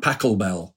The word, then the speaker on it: Pachelbel: And and, on there he did um, a kind of set of versions of an old canon, canon in D major, Johann Pachelbel. I hope I pronounced that right. You have indeed.